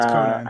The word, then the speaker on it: Conan: Conan, who cares how you say it? It's like a Bowie name. Bowie, who cares? – I do, it's Conan.